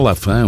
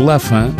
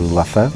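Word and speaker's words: Laffan: O que pouca gente sabe é que Lafan não é a pronúncia correta. – Lafan vem da minha mãe, que é inglesa. Uh, toda a gente acha que é francês, mas não, é, é inglês.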